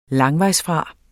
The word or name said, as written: langvejsfra